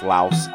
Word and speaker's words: Laos: On the other side, a more contemporary communist nation that we don't really hear much about anymore, and that's in Southeast Asia, the country of Laos, often pronounced Laos, L-A-O-S.